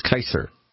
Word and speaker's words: Caesar: So, right, just like people are used to saying Julius Caesar, but in Latin it's a hard C, so it would technically be Julius Kaiser.